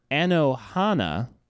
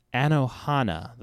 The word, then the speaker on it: Anohana: And so the next one, Quinn, is Anohana, : The Flower We Saw That Day. – Ooh. – Yes. – Ooh. – Anohana, The Flower We Saw That Day. – Can you spell that? – A-N-O-H-A-N-A, The Flower We Saw That Day.